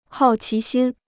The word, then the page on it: 好奇心: hào qí xīn